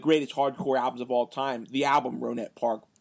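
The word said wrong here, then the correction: Rohnert Park: I also it's Ronard Park. It's the T is actually pronounced like a D out here. I don't know if that's just how people pronounce it out here or not.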